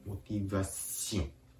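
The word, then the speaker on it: motivation: フランス語のこの微インというのは、そのカタカナ発音、この場合ではモティバシオンシオンと言いながら、同時に鼻から息を抜かすようにそのシオンを発音するところです。モティバシオン、モティバシオンです。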